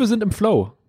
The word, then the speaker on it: Flow: Es geht um den Flow, nicht den Floh. Also nicht das Tier, sondern Englisch den Fluss.